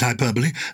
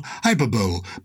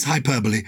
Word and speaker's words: hyperbole: I pronounce it hyperbole. Some people say, as in Super Bowl, hyperbole, but it is hyperbole.